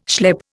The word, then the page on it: schlep: Indian